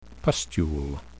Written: pustule

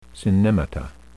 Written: synnemata

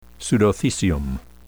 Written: pseudothecium

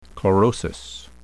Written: chlorosis

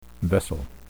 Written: vessel

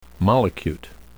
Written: mollicute

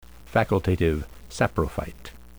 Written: facultative saprophyte

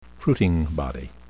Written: fruiting body

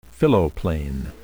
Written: phylloplane

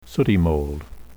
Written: sooty mold

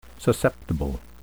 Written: susceptible

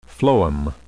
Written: phloem

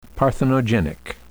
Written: parthenogenic

